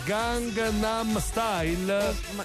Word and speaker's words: Gangnam: Si pronuncia Gangnam Style.